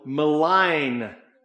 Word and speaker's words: malign: There are going to be people who will malign. The G is silent, but they will malign you.